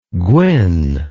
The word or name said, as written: gwyn